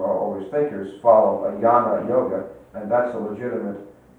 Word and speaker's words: jnana: But Hinduism has four yogas, and they are a jnana yoga, usually they spell it J-N-A-N-A, J-N-A-N-A, which is yoga of knowledge, that's a thinking yoga.